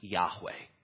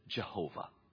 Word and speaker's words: Yahweh: As best we can tell, it was pronounced Yahweh. Earlier generations, you can find it in our Psalter hymnal, would pronounce it Jehovah.